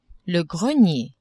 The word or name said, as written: grenier